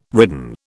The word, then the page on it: ridden: British English